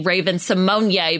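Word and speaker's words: Raven-Symoné: But I think there's definitely clues, like you said, that can lead to it being Raven Simone, because that's how you say her name, as being the Black Widow.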